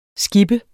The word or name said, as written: skipper